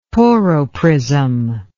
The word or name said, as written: Porro prism